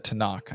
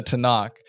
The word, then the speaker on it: Tanakh: And so you have that, the Tanakh, I said it wrong. The Tanakh, you have the Torah, the Nevi'im, and the Ketuvim.